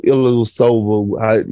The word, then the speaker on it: Ilyasova: It's a lot of vets over there, you know, Ilyasova.